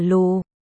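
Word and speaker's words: Lù (鹿): Trong tiếng Trung, chữ Nai, Lu, Lộc và chữ 6, Lu, Lục đều phát âm là Lù.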